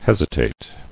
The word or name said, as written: Hesitatingly